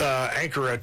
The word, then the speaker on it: Ankara: Ankara, I guess is how you say it. – Say it with authority. – Ankara, Turkey, on an alternate.